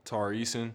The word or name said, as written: Tari Eason